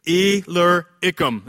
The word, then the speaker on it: Illyricum: Illyricum.